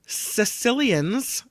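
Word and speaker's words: caecilians: These are ringed Sicilians. It's C-A-E. – Sicilians. – Oh, it's not spelled the same at all.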